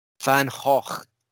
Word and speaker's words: Van Gogh: Van Gogh, as he should be appropriately pronounced.